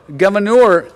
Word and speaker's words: Gouverneur: At the founding of our country, a man by the name of Gouverneur Morris, I can never pronounce that right. It looks like governor, but that's not how it's pronounced.